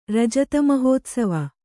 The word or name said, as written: ರಜತಮಹೋತ್ಸವ